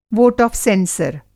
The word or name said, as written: वोट आफ़ सेंसर